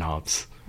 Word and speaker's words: Hobbes: H-O-B-B-E-S. – Oh, okay. Hobbs. Okay. – Yes, like Calvin and Hobbs.